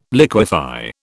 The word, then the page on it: liquefy: British English